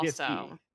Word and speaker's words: GIF: GIF-y. – Obama took a side Obama in 2014 announcing his official position is that it's pronounced GIF like a hard G, like a grape.